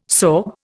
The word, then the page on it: sorb: Indian